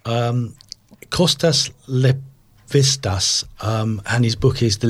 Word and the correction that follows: Lapavitsas: Kostas Lapavitsas, now I'll spell that, C-O-S as in Costa Coffee, C-O-S-T-A-S, with an S on the end, now sold by Whitbread to Coca-Cola, I think that Costa has been.